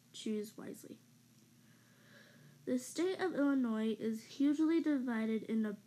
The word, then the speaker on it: Illinois: First things first, the S is silent in Illinois. Nothing annoys an Illinoisan more than when someone pronounces the S in their state's name.